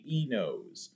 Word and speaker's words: E-nose: They have created the NOS.E, which if you're going to make that a word, it should be the nosy, but they're actually, you pronounce it the other way around, the E-nose.